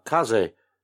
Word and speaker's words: Kaze: I don't know how you pronounce this. Their Kaze label or Kaze label? – Kaze. – Kaze, yeah. – Yeah, it's Kaze. It means wind.